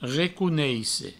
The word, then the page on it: reconeisse: recouneisse